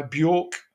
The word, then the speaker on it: Björk: This was five, six years before we met, and it is Play Dead by Björk, I think you pronounce it, with David Arnold.